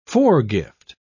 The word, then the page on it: foregift: British English